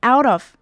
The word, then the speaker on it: out of: 最后我们来看到 out of, 也是跟前面一样要连起来念， T 的音比较不会那么重，会有点发得像的的音，然后 off 的 F 最后也不需要发音发得这么重，就是轻轻的带过就好，所以就会变成 out of, out of.